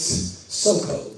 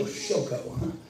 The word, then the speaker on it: Soko: Literally in Hebrew, it's Soko, but I know that it makes us feel better to go Shoko. So we'll just do that.